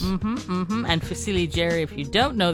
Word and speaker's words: fusilli: Hi, is it Fusilli? Is that how you say it? – Fusilli. – Fusilli Jerry. Yeah. – Fusilli. – Right. Well done.